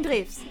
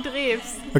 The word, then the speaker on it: Drews: Jürgen Drews. – Okay, so say it again. – Jürgen Drews.